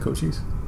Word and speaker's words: Cochise: B, I don't even know how to say his name. Cochise? – That's how you say it, Cochise.